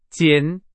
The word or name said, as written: 减